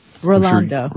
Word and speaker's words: Rolando: But if you were looking for more professional, you know, I'm sure someone like, is it Roland? Roland Gomez, did I get his name right? – Rolando. Yes, he uses it all the time.